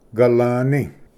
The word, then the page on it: ᎦᎸᏂ: galvni